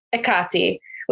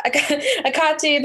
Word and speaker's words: Hecate: It's Hecate. With the H totally silent. So yeah, Akati, the priestess path.